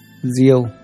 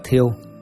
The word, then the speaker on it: thiêu: Diêu, đúng theo chánh âm phải đọc là thiêu, nhưng thường bị đọc trại thành diêu, là miếu thờ những vị tổ đã lâu đời.